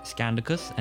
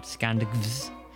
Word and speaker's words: Scandicus: And I have to assume it wants to be pronounced Scandicus and not Scandicvs.